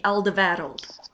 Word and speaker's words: Alderweireld: Which would be Alderweireld.